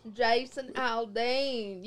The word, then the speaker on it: Jason Aldean: Jason Aldean. You got to say it like that. You got to give it a little bit of country twang.